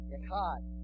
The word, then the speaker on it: Echad: Echad. E-C-H-A-D.